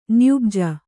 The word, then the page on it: ನ್ಯುಬ್ಜ: myubja